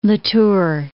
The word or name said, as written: La Tour